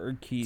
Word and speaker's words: Urquidy: Urquidy, is that how you pronounce it? – Urquidy.